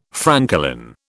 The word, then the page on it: francolin: British English